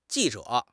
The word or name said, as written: ji4 zhe3